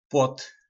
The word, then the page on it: PLot: po nasimu